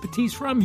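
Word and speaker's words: Batiste Rhum: The H in the rum is the tip of the hat to the French Caribbean, where it's sourced. The H is silent, but I promise you, after you taste Batiste Rum, you won't be silent like me.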